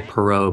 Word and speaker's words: Perrault: I would say Perrault, but yeah, it's probably Perrault.